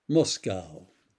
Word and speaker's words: Moscow: The form and rhyme scheme of that poem have been used for this one, which is called Moscow, pronounced in the American way to evoke the original. It represents Dede's reaction to the unprovoked Russian invasion of Ukraine. Moscow, written by Dede.